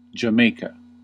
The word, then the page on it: Jamaica: [dʒə.ˈmeɪ.kə]